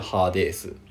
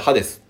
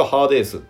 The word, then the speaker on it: ハーデース: ハーデース、まあ、日本語ではハデスって言われよく発音されると思うんですけど一応ハーデースっていうふうに伸ばすらしいで